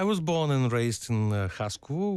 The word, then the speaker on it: Haskovo: It's a small town in the southern part of the country, and I don't want to ruin the pronunciation of it. Haskovo. – Perfect pronunciation.